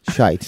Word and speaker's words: Shyte: S-H-Y-T-E. – Yep. – Shite.